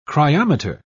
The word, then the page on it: cryometer: British English